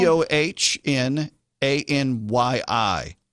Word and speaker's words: Dohnanyi: It's uh, Dohnanyi Dohnanyi Recital Hall. Ever heard of it? – Dohnanyi Recital Hall? D O H N A N Y I. Dohnanyi. Ani.